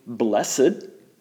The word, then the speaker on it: blessed: Blessed, or using an old pronunciation, blessed, is a word that's used instead of the word happy.